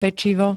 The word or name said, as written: pečivo